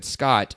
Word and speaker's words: Scott: And I said, Scott. And they said, how do you spell that? And I said, S-C-O-T-T.